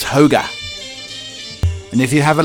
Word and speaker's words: hygge: Hygge, pronounced hoga, roughly means cozy contentment, something that brings out warm, fuzzy feelings.